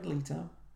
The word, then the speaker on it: Leto: Apparently, Leto.